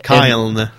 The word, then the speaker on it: Cyln: Et ici, on parle d'un jeu du nom de Kyln, Siln, en tout cas ça s'écrit C-Y-L-N. – Kyln. – C'est ça.